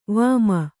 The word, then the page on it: ವಿಮೆ: vime